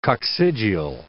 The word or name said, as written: coccygeal